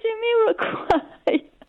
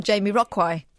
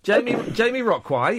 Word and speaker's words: Jamiroquai: How do you say Jamiroquai? – Well, like you just said it then, and not Jamiroquai. – Jamiroquai, yes.